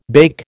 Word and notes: bake: American English

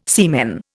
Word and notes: semen: Indian

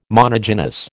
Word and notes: monogynous: American English

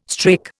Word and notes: strake: Indian

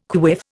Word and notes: quiff: Indian

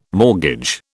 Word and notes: mortgage: British English

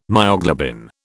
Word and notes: myoglobin: British English

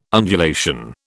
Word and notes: undulation: British English

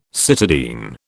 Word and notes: cytidine: British English

